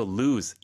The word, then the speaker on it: lose: 잃어버리다는 lose. – Lose. – Z 발음이죠.